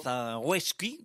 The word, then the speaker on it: Zagajewski: Zagoyevsky, ¿no? – Zagoyevsky, como se pronuncia en polaco, en principio sería Zagajewski, ¿no?